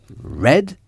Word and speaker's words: read: Read et read s'écrivent de la même façon, c'est-à-dire R-E-A-D. Mais ça se prononce différemment.